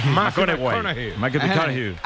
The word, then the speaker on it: McConaughey: Hay que ir corrigiendo la pronunciación. – McConaughey.